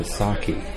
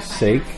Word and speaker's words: sake: The sake. And notice I did not pronounce it sake. I pronounced it sake.